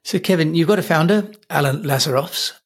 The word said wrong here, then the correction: Lazaros: Is that how I pronounce his name correctly? – Lazarus. Yeah. Lazarus. – Oh, Lazarus. Okay.